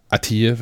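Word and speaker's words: Atiye: Heißt, ähm, Aussprache ist, äh, ich glaube, es heißt Atir, wenn man es richtig ausspricht.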